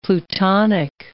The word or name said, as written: plutonic